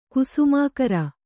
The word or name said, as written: ಕುಸುಮಾಕರ